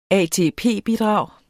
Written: ATP-bidrag

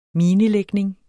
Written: minelægning

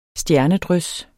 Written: stjernedrys

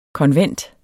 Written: konvent

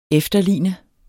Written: efterligne

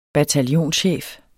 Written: bataljonschef

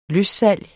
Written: løssalg

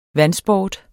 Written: vandsport

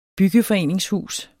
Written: byggeforeningshus

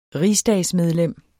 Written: rigsdagsmedlem

